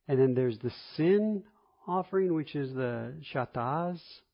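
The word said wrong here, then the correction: Chatat (חטאת): Excuse me. That's a T, not a Z. Sometimes my T's look like Z's. Chatat.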